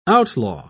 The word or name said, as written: outlaw